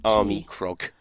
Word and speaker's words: Armikrog: Oh, Army Krog.